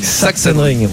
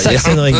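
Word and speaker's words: Sachsenring: Saxenring. – Ouais, tu penses à Sax et tu fais Enring derrière. – Saxenring.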